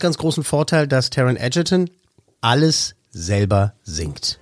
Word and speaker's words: Egerton: Der wird nicht Egerton ausgesprochen, sondern Egerton.